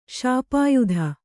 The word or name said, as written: ಶಾಪಾಯುಧ